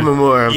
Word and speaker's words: Shimomura: Uh, uh I'm going to butcher this name, like Shimomura, who yeah, also Yoko okay. Shimomura.